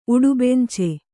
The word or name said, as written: ಉಡುಬೆಂಚೆ